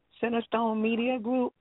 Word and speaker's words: CentrestoneMedia Group: Now, you talked earlier about being the CEO and owner of, I hope I'm saying it right, CentrestoneMedia Group.